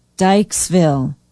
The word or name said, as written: dyckesville